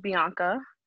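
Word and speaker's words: Bankha: So, first of all, my name is Bianca.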